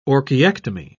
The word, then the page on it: orchiectomy: British English